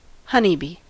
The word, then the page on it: honeybee: American English